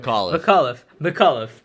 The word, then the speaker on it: McAuliffe: McAuliffe.